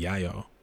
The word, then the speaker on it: Yao: But we do know that a black man, likely from Mozambique, likely a member of the, I think it's pronounced the Yayo people, Y-A-O,